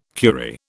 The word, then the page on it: curie: British English